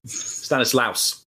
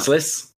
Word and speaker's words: Stanislas: Stanislas. – How long has he been, you know, commentating on Stanislas? How long? How has no one said to him how to pronounce it? It's not difficult, is it?